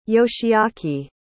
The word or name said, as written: yoshiaki